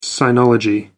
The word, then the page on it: sinology: American English